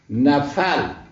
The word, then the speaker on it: نفل: بعد معنی مناسبی برای این کلمه با این تلفظاتی گفته شد که ارتباط به بحث ما داشته باشه دیده نمیشه، الا اینکه این کلمه را به نون و فاء محرکه تلفظ کنیم. نفل،